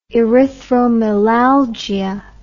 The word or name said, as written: erythromelalgia